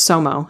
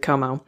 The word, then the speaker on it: COMO: I guess it could be pronounced SOMO, but you pronounce it COMO.